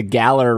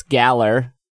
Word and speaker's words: Galar: Speaking of saying things wrong, you both said the Galar region wrong. It's Galar.